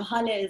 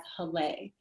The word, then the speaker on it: Haleh: First of all, some people, you know, Haleh is my name. That's how it's pronounced in Farsi. But my non-Persian friends know me as Haleh.